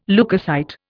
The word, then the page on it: leukocyte: Indian